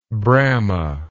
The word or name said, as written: bramah